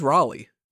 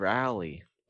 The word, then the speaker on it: Rawley: He always says Raleigh. It's like Raleigh. – It's Rally.